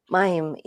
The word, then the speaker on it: Mayim: Did I say that properly? – Mayim? Actually, Mayim, yeah, Vega. It's Hebrew, means water.